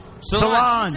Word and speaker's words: Solange: How do you pronounce that? Solange? – Solange.